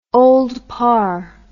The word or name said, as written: Old Parr